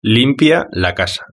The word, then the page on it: limpia la casa: European Spanish